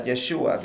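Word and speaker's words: Yeshua (ישוע): La palabra Jesús en hebreo se pronuncia Yeshua, que, que, significa Jehová salva.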